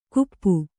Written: ಕುಪ್ಪು

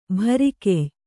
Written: ಭರಿಕೆಯ್

